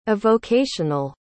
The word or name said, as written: avocational